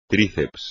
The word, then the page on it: tríceps: espagnol